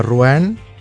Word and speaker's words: Rouen: Són de Rouen, suposo que es pronuncia així. Tu saps francès, jo... – Sí, més o menys, ho has dit força bé.